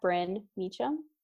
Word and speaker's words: Brynn Mechem: My handle is at Bryn Meacham. Should I spell that? – Yes, please. – Okay. It's Bryn is B-R-Y-N-N, and then Meacham is M-E-C-H-E-M.